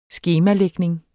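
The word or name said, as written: skemalægning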